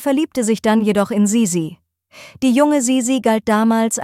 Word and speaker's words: Sisi: Man kennt sie vor allem unter ihrem Spitznamen Sisi, eine Kurzform von Elisabeth. Wegen berühmter Filme über ihr Leben wird der Name oft Sissi ausgesprochen. Das ist aber nicht richtig.